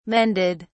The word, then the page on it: mended: British English